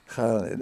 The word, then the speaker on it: Khaled: Well, that's it's a reference to the pronunciation of his name. – Okay, come in and pronounce his name. – Khaled. – And so it's that idea. – It's that Arabic K. – One of those sounds that we in the West aren't very used to doing.